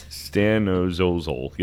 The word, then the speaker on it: stanozolol: I don't know how to pronounce it. Stanozolol. Yep, there you go.